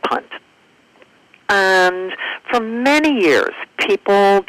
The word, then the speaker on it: Punt: It's P-U-N-T. Is it punt? – Punt, yes.